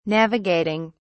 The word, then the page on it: navigating: British English